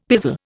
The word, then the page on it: pizzle: Indian